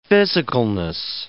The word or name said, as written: physicalness